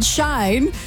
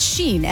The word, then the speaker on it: Shein: And I thought it was called Shine, but apparently I've been pronouncing it wrong the entire time. It's Sheen, S-H-E-I-N.